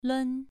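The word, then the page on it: lun: tone 2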